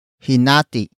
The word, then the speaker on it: hinati: Hinati is spelled as H-I-N-A-T-I. Note the glottal stop at the end.